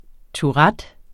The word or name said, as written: tourettes